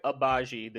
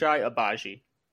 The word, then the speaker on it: Agbaji: It's Oche Agbaji. The G is silent. So Oche Agbaji.